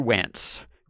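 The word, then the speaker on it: Wantz: Because the Angels and Phil Nevin, replacement for Joe Maddon, he started Andrew Wance, rhymes with pants.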